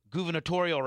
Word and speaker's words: gubernatorial: Hey, Alexa, when's the next Florida gubernatorial race? – Ooh. – Is that how you say it? – Gubernatorial. You got it.